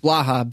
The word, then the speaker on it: Blaha: Blaha, B-L-A-H-A. – It's phonetic. That's what I figured, but I didn't want to say it wrong.